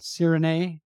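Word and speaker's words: sirinae (сирене): and it's covered with this white crumbly cheese called sirinae, it's pronounced, which is similar to Greek feta cheese.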